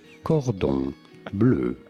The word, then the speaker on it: Cordon Bleu: It is said as Cordon Bleu. Cordon bleu, but it'd be fine in English to say it as cordon bleu.